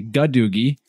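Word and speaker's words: Gadugi: If you happen to catch that part of the Plus extension and you want to go looking that word up, we actually said a doogie, but we found out we had an error in the pronunciation. It's actually gadoogie.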